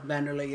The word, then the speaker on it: Wanderlei: Wanderlei, yeah. – Oh, Wanderlei. – Another one. V, and it's a W, but it's pronounced with a V.